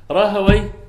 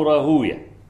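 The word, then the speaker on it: راهويه: راهوي إما تنطق راهوي أو راهوية، الاثنين المحدثون يقولون راهوية، وكل ما جرى هذا المجرى مثلا راهوية، زنجوية، سختوية، سعدوية، تمام، دي ألقاب يعني. أما اللغويون فيقولون وي، راهوي، سعدوي، زنجوي، ها كده يعني.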